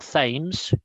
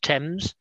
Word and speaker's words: Thames: It's not Thames. The th is not pronounced Thames. It's pronounced Thames.